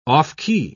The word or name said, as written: off-key